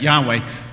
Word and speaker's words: Yahweh: Now, later on, so you might pronounce that Yahweh,